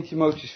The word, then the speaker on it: Timotheus: 1 Timotheus 4 vers 12.